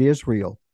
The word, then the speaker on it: Israel: Israel, it is pronounced with the S sound, but if you turn the sheen into the sh sound, you have the word Yasha'el.